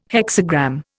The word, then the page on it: hexagram: Indian